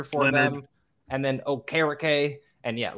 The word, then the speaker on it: Okereke: Okereke. Okereke. That's how I always pronounce it, phonetically.